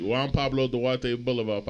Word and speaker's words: Juan Pablo Duarte: Juan Pablo Duarte Boulevard, papa. A couple blocks away from. – Well pronounced. Well said.